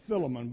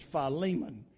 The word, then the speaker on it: Philemon: A lot of people mispronounce that. A lot of times I hear people call it Philemon, but it's not pronounced Philemon, it's Philemon.